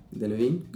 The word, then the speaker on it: Delevingne: Delevingne, como Adam Levine.